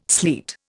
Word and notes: sleet: Indian

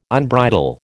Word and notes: unbridle: American English

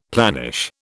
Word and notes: planish: British English

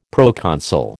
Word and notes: proconsul: American English

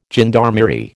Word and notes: gendarmerie: American English

